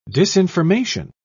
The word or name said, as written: disinformation